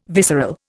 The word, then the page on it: visceral: Indian